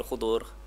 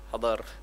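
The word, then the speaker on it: Khidr: Then the word Khidir also can be pronounced as Khidir, Khudur, Hadar, pelbagai lah.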